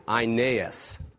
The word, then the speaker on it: Aeneas: There he found a man named, the way you pronounce this is an I, Aeneas.